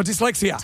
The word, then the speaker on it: dyslexia: Hey, sorry, it's my Lystexia. Is that how you say it? Oh, dyslexia.